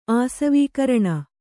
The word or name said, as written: ಆಸವೀಕರಣ